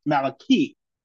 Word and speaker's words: Malachi: Malachi, whose name means messenger or my angel, is actually pronounced Malachi in Hebrew.